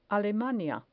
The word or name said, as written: alemania